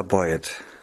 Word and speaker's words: Boy-Ed: Also meiner Ansicht nach Norddeutsch Ida Boyett. Also man zieht es so zusammen. Ja, ganz Trotz des Bindestriches in der Mitte. Also man schreibt sie, haben wir das schon gesagt, man schreibt sie B-O-Y, Bindestrich E Idee.